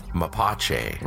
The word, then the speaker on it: Mapache: Mapache.